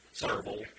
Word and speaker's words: Sevierville: Saraville. Say it right. Saraville.